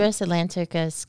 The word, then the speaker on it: Cedrus atlanticus: Is it Cedrus atlanticus? Is that how you would pronounce it? – Cedrus atlanticus glauca. Yeah, Glauca, yeah.